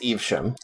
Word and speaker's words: Evesham: Did you ever find out how to pronounce that town? – Evesham. – Is it Evesham? Okay. – It's Evesham. – Okay.